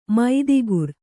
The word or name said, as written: ಮೈದಿಗುರ್